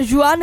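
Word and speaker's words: Jwan: Qua si chiama Juan Josef. Non so, non si pronuncia sicuramente così. – Juan. – È J-W-A-N.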